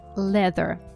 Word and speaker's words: leather: Очень часто неправильно произносят это слово. Обратите внимание, там звук л. Э. Leather.